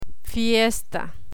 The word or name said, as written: fiesta